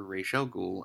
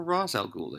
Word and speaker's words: Ra's al Ghul: So Malcolm Merlyn, who's upset with Oliver because he's no longer Ra's al Ghul and lost a hand, or Ra's al Ghul, they pronounce it both ways on the show,